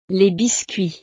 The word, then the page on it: les biscuits: frances